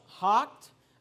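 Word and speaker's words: houghed: And David, notice this word, it's pronounced hocked.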